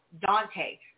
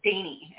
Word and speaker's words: Dante: D-A-N-T-E spells Dante. So like the Dante Church, which is what I've always called it, I was corrected, and they pronounce it Dainty.